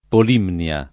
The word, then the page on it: Polimnia: Polinnia; lingua italiana